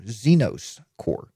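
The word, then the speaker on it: Zenoss Core: Z-E-N-O-S-S Core. Zenos Core.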